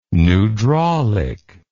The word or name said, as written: pneudraulic